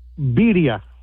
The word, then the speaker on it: birria: But it's pronounced birria.